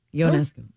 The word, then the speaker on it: Ionescu: Spelled with an I, I-O-N-E-S-C-U. It's pronounced Yo. Ionescu.